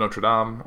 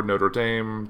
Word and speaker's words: Notre Dame: So, the Notre Dame de Paris means Our Lady of Paris in French, often referred to as simply as Notre Dame or Notre Dame, depending on how you want to pronounce it.